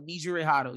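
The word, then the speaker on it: Nejire Hado: I think that's how you pronounce her name. – Nijire Hado. Nijire Hado.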